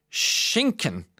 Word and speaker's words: Schinken: A thick, heavy, bang on the table book is referred to as a Schinken. S-C-H-I-N-K-E-N. Pronounced like Schinken. Schinken.